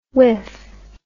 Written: wiff

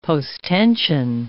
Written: posttension

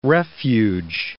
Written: refuge

